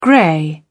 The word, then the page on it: grey: British English